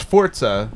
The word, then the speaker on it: Forza: it's Forza, like, because it's Italian. But when they're talking about Assassin's Creed, they always say Ezio, even though it's E-Z-I-O.